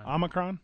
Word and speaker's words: Omicron: That Omicron, am I saying it right? Omicron? – Omicron.